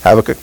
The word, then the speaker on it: Habakkuk: I always say Habakkuk. How do you say it? Habakkuk.